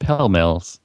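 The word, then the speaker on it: Pall Mall: Pall Malls, which are apparently originally pronounced Pell Mills.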